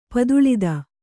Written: ಪದುಳತೆ

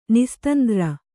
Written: ನಿಸ್ತಂದ್ರ

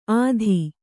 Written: ಅತ್ತಿ

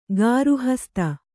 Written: ಗೃಹಸ್ಥೆ

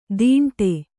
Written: ತಿಂಡೆ

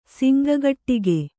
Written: ಸಿಂಗಗಟ್ಟಿಗೆ

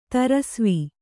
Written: ದಾರಿಸವೆ

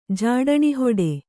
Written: ಜಾಡಣಿಹೊಡೆ

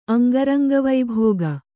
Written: ಅಂಗರಂಗವೈಭೋಗ

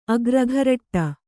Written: ಅಗ್ರಘರಟ್ಟ

